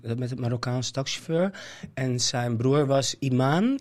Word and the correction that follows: Imam: Imam, zeg ik dat goed? Imam, imam. Een, hele heilige, zeg maar.